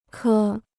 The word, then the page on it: 颗: kē